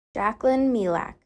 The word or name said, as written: Jaclyn Meilach